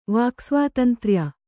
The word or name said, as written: ವಾಕ್ಸ್ವಾತಂತ್ರ್ಯ